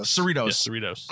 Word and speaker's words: Cerritos: Well, and wait, and don't you want to see, is I'm pronouncing this wrong, the Cerritos, what's their ship? – Cerritos.